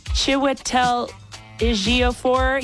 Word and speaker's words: Chiwetel Ejiofor: Chiwetel Ejiofor. – You said that perfectly. Good job.